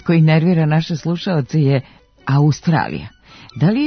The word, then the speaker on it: Australija: Oblik Australija naveden je u matičinom rečniku sa kratkouzlaznim akcentom na drugom slogu i dužinom na sledećem, tamo gde se u razgovornom jeziku obično čuje dugosilazni akcenat, što je van akcenatske norme, pošto silazni akcenti mogu da stoje samo na prvom slogu u reči.